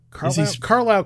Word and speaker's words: Carlisle: He's the Carly's Crown Court. Carlisle Crown Court.